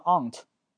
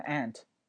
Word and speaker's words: aunt: 这个里面啊，我们讲一下 aunt 这个单词，英式发音会读成 aunt，那美式发音会读成 aunt，对吧？aunt， my aunt Jennifer is an actress。